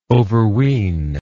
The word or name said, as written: overween